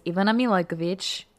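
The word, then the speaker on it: Ivana Milaković: Okay, so the correct way to pronounce my name in Serbian is Ivana Milakovic.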